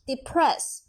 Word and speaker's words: depress: 第三人称单数 depress 在后面加 es，这个呢是比较注意的是第三人称单数啊，后面呢是加 es。发音呢我们要注意它的重音是在后面 press。